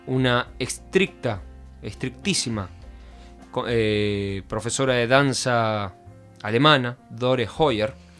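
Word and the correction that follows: Hoyer: Joya, mejor dicho, si lo pronunciara mejor, quien la formó en el Teatro Argentino de La Plata.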